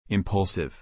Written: impulsive

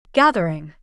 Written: gathering

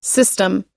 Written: system

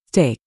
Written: Steak

